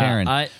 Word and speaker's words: Taron: Taron.